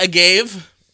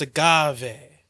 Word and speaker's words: agave: Agave cerveza, because in Spanish, E's are pronounced E. – So it's not agave? – No, it's agave.